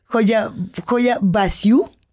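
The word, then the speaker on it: Hoia Baciu: ¿Pueden decirle Coyabasiu? Joya Basiu. Se escribe con H, como joya, con I, ajá, H-I. – Ajá, es que incluso se confunde a veces, si lo ves rápido, como hola.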